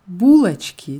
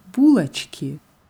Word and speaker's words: булочки: Булочки. Die Betonung bleibt auf u, Булочки.